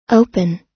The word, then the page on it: open: American English